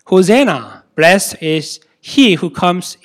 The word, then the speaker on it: Hosanna: Its right pronunciation is Hosanna. Hosanna.